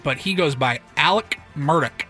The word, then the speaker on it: Murdaugh: It's Murdoch.